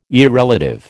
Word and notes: irrelative: American English

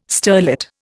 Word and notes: sterlet: Indian

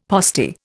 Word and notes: postie: Indian

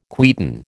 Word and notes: quieten: American English

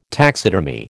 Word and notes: taxidermy: American English

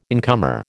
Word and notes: incomer: American English

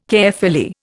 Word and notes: Caerphilly: Indian

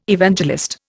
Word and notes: Evangelist: Indian

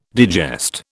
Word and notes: digest: British English

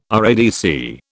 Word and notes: RADC: British English

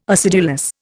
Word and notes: acidulous: Indian

